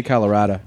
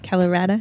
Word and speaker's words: Colorado: That's not how they say it. – They say Colorado. – Colorado. – That's how the locals. You sound real weird if you don't say Colorado.